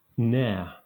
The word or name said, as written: nare